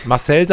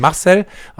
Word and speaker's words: Marcel: Betont man ja im Deutschen immer die falsche Silbe. Also indem man ja auch nicht Marcel sagt, sondern Marcel, haben wir auch schon ein paar Mal gesagt in unseren Folgen.